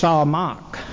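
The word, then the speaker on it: samach (שמח): The Hebrew word is S-A-M-A-C-H, and it's pronounced samach, samach, emphasis on the last syllable.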